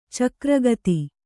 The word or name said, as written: ಚಕ್ರಗತಿ